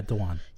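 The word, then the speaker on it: Dhawan: His name is Sasha, and I cannot pronounce. I think it's Dewan. – Yeah, Dewan.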